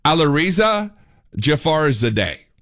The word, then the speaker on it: Alireza Jafarzadeh: Alireza Jafarzadeh.